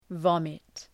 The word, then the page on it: vomit: ‘vɒmıt